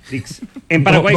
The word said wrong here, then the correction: disc: no, no, espera. Dix no es. D-I-S-C. Claro, disc.